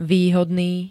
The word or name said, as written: výhodný